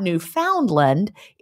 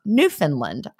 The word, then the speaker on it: Newfoundland: Just to help you start, it's not Newfoundland. It's pronounced Newfoundland. I almost did it, right? I was saying Newfoundland. Mm-hmm. I was putting the D in there, though.